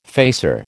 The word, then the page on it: facer: American English